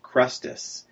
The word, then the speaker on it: Chrestus: But what Suetonius says here is that the Jews were causing a disturbance because of the instigation of Crestus, and that's spelled C-H-R-E-S-T-U-S.